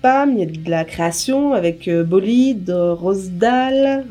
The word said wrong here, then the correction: Rosdal: je ne sais pas si je prononce bien. – Rosdal. Rosdal. – Rosdal, ah.